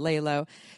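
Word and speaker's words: Lelo: Lalo, it's L-E-L-O, but we pronounce it Lalo.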